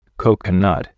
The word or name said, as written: coconut